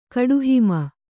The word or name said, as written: ಕಡುಹಿಮ